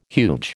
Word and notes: huge: American English

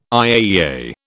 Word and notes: IAEA: British English

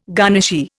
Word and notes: garnishee: Indian

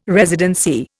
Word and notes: residency: Indian

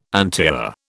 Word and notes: antiar: British English

